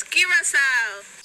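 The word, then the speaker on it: Curaçao: Let's start with how you should not pronounce it: Curacao, Curaco, Curacao, Curacao, Cucarachao, Curacao. The correct way to say the name of a beautiful Caribbean island. And the world famous liquor is Curacao.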